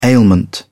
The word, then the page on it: ailment: British English